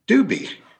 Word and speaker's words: Doobie: And music lovers in your audience will appreciate the pronunciation is Doobie.